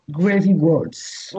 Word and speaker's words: Gravy Waltz: Nous allons écouter Gravy Waltz. Est-ce que je prononce bien? Gravy Waltz. – Oui.